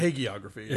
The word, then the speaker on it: hagiography: When I heard this was coming, I just thought, Hagiography, or is it Hagiography? – Hagiography, yeah. – It's Hagiography. I was going to say Hagiography, but it's Hagiography, yeah? That's how you pronounce it? – That's how my understanding is.